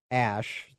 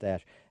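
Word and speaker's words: Æ: X Ash, the AE together, pronounced Ash, X Ash A12.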